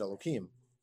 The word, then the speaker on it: Elohim: Well, the pasuk always says Elohim, but when it's referring to God, we pronounce it Elohim.